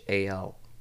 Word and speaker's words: Heyerdahl: It's Chris Heyerdahl. – There it is. It's Nordic. It's hard to pronounce. – Wow. It's Heyerdahl.